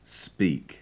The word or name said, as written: speak